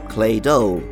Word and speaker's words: Claydol: The name, Claydol, quite literally comes from Claydol, but it also rhymes with dreidel, a type of spinning top used during Hanukkah celebrations in Judaism.